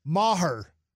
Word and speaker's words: Maher: Maher. That's how you say his name.